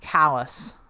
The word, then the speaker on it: Calais: Uh, in the in the UK, the place is called Calais. – Uh, uh in the US, it's also called Calais, but traditionally, it's pronounced Callus.